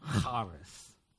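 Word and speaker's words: charis (χάρις): The Greek word, now, the Blue Letter Bible pronunciation of that word is charis, but that's hard for me to say. So I'm just going to phonetically say it, charis or charis.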